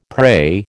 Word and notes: pray: American English

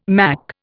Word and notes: mack: Indian

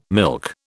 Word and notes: milk: British English

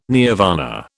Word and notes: nirvana: British English

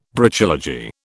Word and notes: Brachylogy: British English

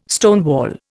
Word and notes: stonewall: Indian

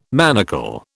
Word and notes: manacle: British English

